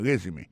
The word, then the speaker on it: resume: I see from your resume, moi ouais, dans CV ou dans curriculum vitae, regardez qui j'ai prononcé mon résumé. I see from your resume, accent tombé sur premier syllabe là.